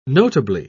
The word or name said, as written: notably